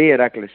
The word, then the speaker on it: Heracles: Yo digo Heracles, ¿se dice así en holandés o cómo se dice? – Sí, Heracles, sí. – Heracles, sí. – Oh, qué fácil, se lee cómo se escribe.